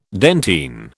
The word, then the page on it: dentine: British English